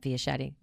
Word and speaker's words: Fischetti: So if you didn't know that, with my name, Bernadette Fischetti, rhymes with spaghetti.